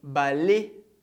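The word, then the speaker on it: ballet: Por exemplo, balai. Balai, a gente não pronuncia o T.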